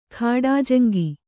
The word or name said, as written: ಖಾಡಾಜಂಗಿ